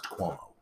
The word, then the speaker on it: Cuomo: You mean it's pronounced Cuomo. – Oh, Cuomo.